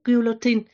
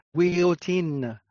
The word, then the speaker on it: guillotine: Trong tiếng Pháp phát âm là guillotine.